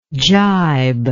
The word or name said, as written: gibingly